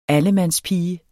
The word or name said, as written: allemandspige